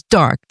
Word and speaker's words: d'Arc: Her family name was Arc, was Dark, D-A-R-C.